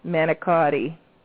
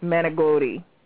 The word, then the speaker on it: manicotti: And the only way, the quickest way to get somebody like that upset is to mispronounce an Italian word, like manicotti. That's wrong. It's manigotti. Manigotti.